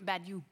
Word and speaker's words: Baudoux: Staal ziet Baudou, zeg ik het goed, Baudou? Badu. Badu.